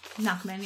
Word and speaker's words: Nachman: But uh, is it Nachman? – Nachman, yeah.